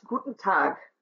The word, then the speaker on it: Guten Tag: So, for example, Nummer 1 is Guten Tag. That syllable that's stressed the most is the last of the three syllables.